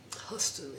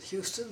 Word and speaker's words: Huston: Huston, H-U-S-T-O-N, but pronounced Huston. – Mm. – Everybody else pronounces it Houston, but we pronounce it Huston.